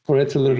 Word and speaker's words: collateral: Collateral bir damage. – Collateral damage. Okuyamıyorum ama hani collateral diye mi okunur? Nasıl okunur? – Collateral.